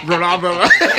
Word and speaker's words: Garoppolo: Garoppolo.